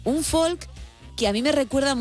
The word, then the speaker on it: folk: Empezamos con un folk o folk, como dirían ellos. Esto lo debatimos en su momento. En el No Sonoras me mirasteis con cara rara. Bueno, pues no pronuncian la L.